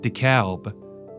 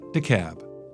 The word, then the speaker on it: DeKalb: Outsiders always try to call it DeKalb, but we pronounce it DeKalb.